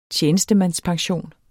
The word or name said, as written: tjenestemandspension